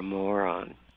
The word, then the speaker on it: Moran: You asked me to research the family name, M-O-R-A-N. And in Ireland, it's pronounced moron.